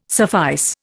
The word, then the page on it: suffice: Indian